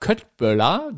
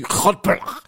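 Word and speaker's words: Köttbullar: Aber jedenfalls hat die immer gesagt, Köttböller, so wie wir das aussprechen, wird es ja gar nicht ausgesprochen. Der Schwede sagt irgendwie, Köttböller.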